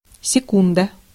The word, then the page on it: hetki: /ˈhet.ki/